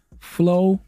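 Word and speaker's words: Florida: Flo-ri-da.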